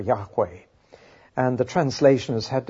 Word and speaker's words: Yahweh: And the 4 letters are actually J-H-V-H, the 4 letters of God's name. That's pronounced Jehovah in English and Yahweh in Hebrew.